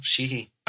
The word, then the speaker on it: Sheehy: Yeah, no, Sheehy.